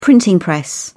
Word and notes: printing press: British English

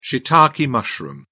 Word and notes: shiitake mushroom: British English

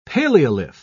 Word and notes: paleolith: British English

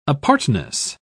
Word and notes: apartness: British English

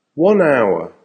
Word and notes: 1 hour: American English

